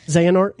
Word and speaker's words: Xehanort: What was it, Xehanort? – Xehanort, yeah. – Is that the right way or the wrong way? – Yeah, no, you got it right.